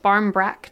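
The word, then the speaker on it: barmbrack: my guess is an Irish bread called Barn Breek. I could be pronouncing this horribly wrong. It's pronounced Barn Brack today, but it was made in the shape of a wheel to represent the moon.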